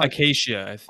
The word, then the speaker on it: acacia: And the ingredients, by the way, oil of Abramelin, which is a ceremonial magic oil blended with aromatic plant materials, and then the ancient Akia. How did we say this one now? – Acacia. – Acacia, that's it.